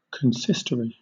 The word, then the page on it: consistory: British English